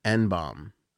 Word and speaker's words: Ehnbom: Barbro Enbom. – Okay. – E-H-N-B-O-M. How would you say that? Enbom. – Yeah. – Right? – Yep.